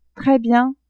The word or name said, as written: tres bien